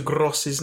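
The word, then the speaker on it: Groß: So if you'd like to all take a listen to this, this is how you pronounce Mr. Gross's name. – Hi, gaffers. Gafferazzo. Just giving you a little bit of German education here, since I was born in the Vaterland. That big B actually represents 2 S's next to each other, because that is quite a common thing in the German language.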